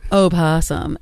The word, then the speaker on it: opossum: The O in opossum is spelled but not pronounced. It is a silent letter, just like the H in honest.